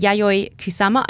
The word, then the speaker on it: Yayoi Kusama: We were wandering along and I just saw a big poster for a Yayoi Kusama.